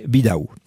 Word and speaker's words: Vidal: Alors, c'est-à-dire la forme locale du prénom, en fait, c'est un prénom, hein, Bidaou, c'est Vidal, qui est, vous savez, le V se prononce B à l'origine, ici, et puis le L, à la finale, se vocalise en E. Alors, bien sûr, il est incorrectement orthographié par l'état civil français, hein, c'est un V et non pas un B qu'on devrait donc avoir à l'initiale, puisque ce V se prononce toujours chez nous, B, comme en Espagne.